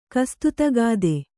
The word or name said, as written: ಕಸ್ತುತಗಾದೆ